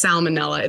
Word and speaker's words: salmonella: Salmonella, I just found out I've been saying that word wrong, and now I said it wrong again. It's salmonella. It's not like the fish.